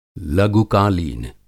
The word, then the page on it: लघुकालीन: Hindi